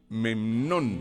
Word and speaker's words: Memnón: Memnón. Ah, oye, este sí no lo había. – Memnón. – Memnón. – ¿Y usted cómo se escribe? No, M-E-M-N-O-N. Memnón.